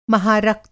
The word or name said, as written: महारक्त